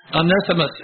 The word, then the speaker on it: Onesimus: I'm not saying it very well. I was saying it right all day, but I can't say it right now. Omniscience. Is that it? Somebody? What is it? No, you can't do it. Omnissimus, I believe, is how you say it.